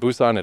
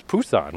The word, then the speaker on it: Busan: And Busan isn't Busan at all, it's Pusan with a P. I don't know why it's spelled with a B, but that's not how they pronounce it.